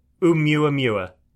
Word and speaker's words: Oumuamua: It's Oumuamua.